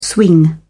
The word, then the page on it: swing: British English